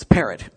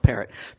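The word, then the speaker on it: Parrott: Dr. Les Perot. Oh, actually, it's Parrot. I want to say Perot, but his name, it's actually pronounced Parrot.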